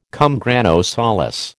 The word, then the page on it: cum grano salis: American English